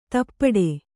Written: ದುಪಟಿ

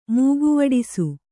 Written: ಮೂಗುವಡಿಸು

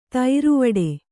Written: ಥರವಿಡಿ

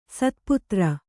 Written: ಶತಪತ್ರ

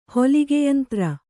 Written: ಹೊಲಿಗೆಯಂತ್ರ

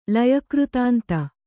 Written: ಲಯಕೃತಾಂತ

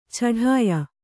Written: ಛಢಾಯ

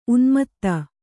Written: ಉನ್ಮೋದ

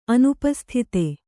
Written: ಅನುಪಸ್ಥಿತ

